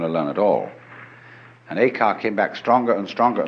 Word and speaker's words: Eka: Eka is Japanese pronunciation, like Zen is the Japanese pronunciation of the Chinese Chan.